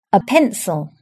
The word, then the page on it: pencil: British English